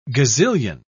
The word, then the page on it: gazillion: British English